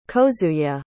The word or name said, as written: kozuye